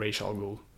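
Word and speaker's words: Ra's al Ghul: Men tydligen enligt Neil Adams, ja, eh, Neil Adams hade någon dotter som hade gått till en så här språkforskare uh, och frågat hur du uttalar man det här. Och då var det tydligen racial goal.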